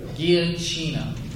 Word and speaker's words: Giacchino: Michael Giacchino? – Giacchino. – All right. He has composed for two other Pixar films, The Incredibles and Ratatouille. Also, he composed the score for Up. Giacchino has built a career...